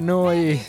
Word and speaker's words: Neu: Si pronuncia, no, si scrive Neu, si pronuncia Noi, si significa nuova.